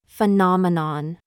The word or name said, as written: FIFE